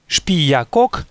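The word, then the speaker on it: Xpiyacoc: Говоря о сотворении мира, книга Пополь Вух называет прародителей мира именами Шпиякок и Шмукане. Отметим для дальнейшего, что начальная буква X в определенных словах киче и в начале собственных имен произносится как ше.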